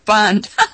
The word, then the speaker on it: Bund: I said it right this time. Instead of Bund. Bund. Okay. See, I'm not a German speaker.